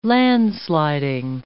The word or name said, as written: landsliding